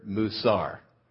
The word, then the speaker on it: musar: That word instruction in the Hebrew is the word musar, M-U-W-C-A-R. That's not how we would spell it. You know, not like moo, like the cow, but M-U-W.